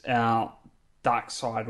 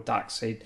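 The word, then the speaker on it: Darkseid: is the DC Universe Classics Dark Side or Dark Seed, depending on how you want to pronounce that.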